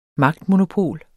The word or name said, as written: magtmonopol